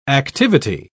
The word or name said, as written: activity